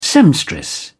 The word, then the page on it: seamstress: British English